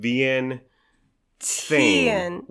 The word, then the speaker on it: Vientiane: We don't know how to officially pronounce V-I-E-N-T-I-A-N-E. – We don't know what we're doing. – We began lovingly, affectionately calling it Vientiane because my mom's name is Tien. – Oh, yeah. – T-H-I-E-N. – Vien for Vietnam. – Right. – Tien for your mom, Tien.